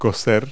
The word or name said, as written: bake